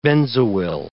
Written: benzoyl

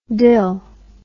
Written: dil

